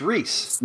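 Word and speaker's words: Rhys: And Matthew, I want to say his name is Rice. – I think it's Reese. – Might be Reese. I think you're right.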